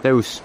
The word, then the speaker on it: Reus: Reus.